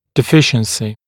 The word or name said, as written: deficiency